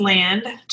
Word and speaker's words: Land: Last name is Land. Just like, the land that you stand on.